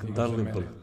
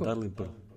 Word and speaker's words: Dalrymple: Mark Dremfile ili tako nešto. Koji je isto, on je recimo ono Advanced macOS Programming. Koji je isto jedno od referenta. – Mark Dalrymple. – Kako? – Dalrymple.